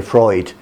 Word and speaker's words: Freud: Alors, on parle de Freud. Et en anglais, on essaye de prononcer à l'original, donc c'est Freud.